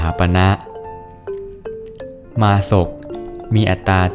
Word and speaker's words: มาศก: สวัสดีครับพบกับรายการคำวัดวันนี้ขอเสนอคำว่ามาศกมาศกสะกดด้วยมอม้าสละอาสอเสือกอไก่อ่านว่ามาศกมาศก